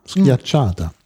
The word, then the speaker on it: Schiacciata: Wie spricht man dieses Brot denn richtig aus? – Ich hab, muss mal gucken. Ich habe mich so dem. Ach, die Schiacciata. Schiacciata.